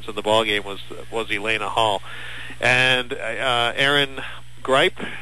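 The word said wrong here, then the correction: Griep: got, or Greep, I'm sorry, Greep, G-R-I-E-P, that's the European pronunciation, had three baskets, 0 for 4 from the foul line, and 6 points overall.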